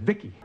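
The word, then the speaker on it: VICI: V-I-C-I, I pronounce it Vicky.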